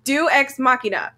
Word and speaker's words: Deus Ex Machina: I know how to say it. – Okay. – It's Deus Ex Machina. – Deus Ex Machina.